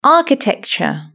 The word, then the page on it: architecture: British English